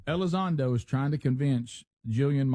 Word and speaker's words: Elizondo: E-L-I-Z? – Lewis. Lewis Elizondo. – Okay. – I don't know who that is. – Is that how you say his name? Okay. – He's kind of scary looking. – Elizondo? – Elizondo.